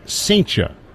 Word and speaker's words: Cijntje: It's spelled C-I-J-N-T-J-E. Here's the throw to first, and Murray dives back. C-I-J-N-T-J-E. So it's got four consecutive consonants, two of which are J's. It's pronounced Saintia, like Saint Cha.